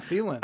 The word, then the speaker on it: Thielen: Minnesota, they spell it. T-H-I-E-L-E-N, so it's kind of pronounced like Thielen. So everybody goes like Thielen or Thielen is another one way they pronounce it.